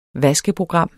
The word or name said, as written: VASKEPROGRAM